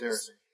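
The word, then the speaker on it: Déraciné: PlayStation VR game called Deracine. Or Duracine. I think I'm mispronouncing that. – Deracine. – Because there's two... Well, there's... The accents are over the E's.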